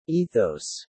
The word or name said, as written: ethos